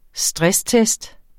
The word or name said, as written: stresstest